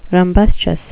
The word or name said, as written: rumbustious